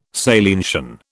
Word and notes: salientian: British English

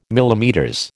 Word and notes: MM: American English

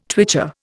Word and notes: twitcher: Indian

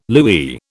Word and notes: louis: British English

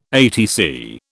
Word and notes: ATC: British English

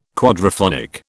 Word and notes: quadraphonic: British English

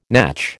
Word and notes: natch: American English

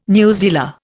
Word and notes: newsdealer: Indian

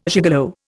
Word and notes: gigolo: Indian

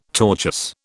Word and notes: tortuous: British English

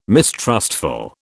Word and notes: mistrustful: British English

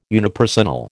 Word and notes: unipersonal: American English

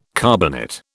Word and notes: carbonate: British English

